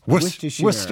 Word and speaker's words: Worcestershire: White wine Worcestershire, which I can't pronounce. – Worcestershire.